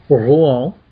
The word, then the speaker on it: Rouen: Yeah, so let's go on up the Loire River in the beautiful country of France and go to, as the French pronounce it properly, Rouen. R-O-U-E-N, or Rouen, or Rouen, or Rouen, if you, you know, you want to put it that way.